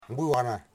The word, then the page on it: guwara: Wamut